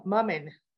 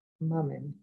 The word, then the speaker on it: Moomin: Moomin is how you pronounce it, right? Moomin. Okay. You guys, I appreciate you. And I'm, oh, Mummin, says Ellen. Mummin. Okay.